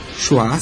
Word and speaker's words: Shoah: Shoah se escreve com SH e com H mudo no final.